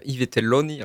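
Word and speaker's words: I Vitelloni: Il y a un classique quand même, hein, dans la programmation, Yves Telloni, alors je pense que je prononce très mal, de Federico Fellini. – Très bien prononcé.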